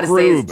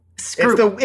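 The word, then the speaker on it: Skroob: Scroob. – To say it. – Scroob. – Stroop. I'm like saying it wrong. Stroop. I'm going to still say it. – Scroop. It's the letters of Brooks mixed up.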